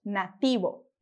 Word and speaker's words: nativo: E para fechar os nossos sons de hoje, a gente tem a palavrinha nativo, que significa nativo, e que a gente vai pronunciar como nativo, nativo.